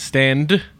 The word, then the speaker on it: Stand: It's Stand. – Close enough. – You gotta pronounce the D.